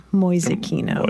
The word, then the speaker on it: Mäusekino: M-A umlaut U-S-E-K-I-N-O, Mäusekino.